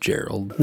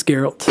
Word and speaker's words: Geralt: And it's pronounced Gerald. – No, it's Geralt.